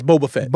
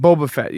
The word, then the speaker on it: Boba Fett: How do you say that? The fan bros would be upset with me. – Yes, Boba Fett. – Boba Fett, yeah.